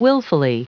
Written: willfully

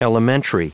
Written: elementary

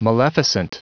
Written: maleficent

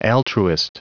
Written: altruist

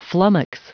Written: flummox

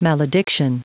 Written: malediction

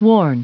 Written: warn

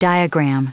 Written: diagram

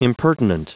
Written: impertinent

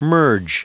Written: merge

